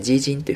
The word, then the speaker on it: 基金: まあ、日本でもですね、基金、何とか基金というふうに言うと思うんですけども、中国語でファンドは基金という漢字を書きます。え読み方ですね、ジージンというふうに読みます。